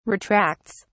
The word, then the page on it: retracts: British English